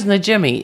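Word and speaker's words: Najimy: Because her name is Najimi. It's, you say it like it looks. It's not like some weird... – It's a weird name, but it's pronounced phonetically.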